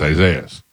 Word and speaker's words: Isaias: And then it was spelled out phonetically, E-E-S-A-H-E-E-A-H-S. – And right, like, it's spelled phonetically, it's Isaias.